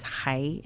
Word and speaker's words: chai: And the word I've been talking with, actually Terry Johnson and I have been talking about the word raw, that the Hebrew word for life is chai, and it means, I'm not saying that correctly, it's more like your guttural, kind of thing.